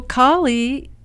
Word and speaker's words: Kali: So Kali is with a long A.